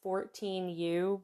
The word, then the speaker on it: 14U: It's written as 14U, but it is apparently pronounced 1-4-U, who had 14, but they recently disbanded due to issues with the company.